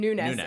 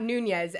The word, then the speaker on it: Nunes: Pronounces his name Nunes, and not Nunez, as you would imagine.